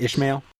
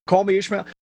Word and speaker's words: Ishmael: Did I say that right? Ishmael? – Call Me Ishmael.